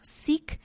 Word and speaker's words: Seek: Y recientemente subimos de otra que se llama SIC, eh, se escribe S-E-E-K.